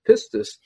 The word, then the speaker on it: pistis: And the word is spelled in English, P-I-S-T-I-S, pistis, I guess is how you pronounce it.